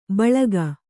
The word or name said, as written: ಬಳಗ